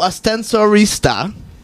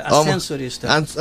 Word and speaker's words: Ascensorista: O Ascensorista. Is that, am I pronouncing it right? – Almost there. Ascensorista.